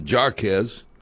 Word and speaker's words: Jarquez: Now, his name is spelled J-A-R-Q-U-E-Z. I pronounce it Jarquez. He pronounced it Jarquez.